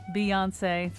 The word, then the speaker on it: Beyoncé: Kion Wolf, Beyonce, three syllables. The O followed by the N.